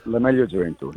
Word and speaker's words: La Meglio Gioventù: La Meglio Gioventù, yeah.